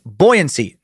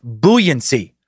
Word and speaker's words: buoyancy: But man, it's buoyancy, not buoyancy. And this is not up for debate. There are not multiple pronunciations, I promise. Excuse me, it's buoyancy. You do call buoys, but the word buoyancy is buoyancy.